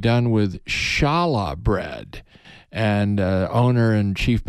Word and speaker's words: challah: And it's challah.